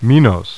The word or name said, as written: Minos